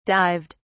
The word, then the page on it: dived: British English